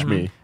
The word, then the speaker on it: Shmi: Back on Tatooine, Anakin's mom, Shmi. Is that how you say her name? – Yeah. – Shmi.